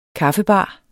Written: kaffebar

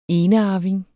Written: ENEARVINGER

